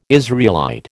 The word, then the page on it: Israelite: American English